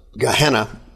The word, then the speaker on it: Gehenna: Everyone who died and went to hell then transitions into the lake of fire, or the Greek word is Gehenna. We usually pronounce it Gehenna.